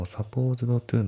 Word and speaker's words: supposed to: まあ、あと発音ですけど、 Supposed to のその D がね、抜けますね。 Supposed to、 Supposed to じゃなくて Supposed to ですね。